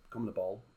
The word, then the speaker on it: CONMEBOL: best teams from the CONMEBOL.